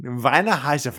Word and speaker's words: Weinheiser: I believe it's Vine. Weinheiser. It's very long sounding.